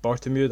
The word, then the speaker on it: Bartomeu: I think it's likely it's going to be a real power struggle between him, the president, is it Bartomeu? That's how you pronounce it, Bartomeu.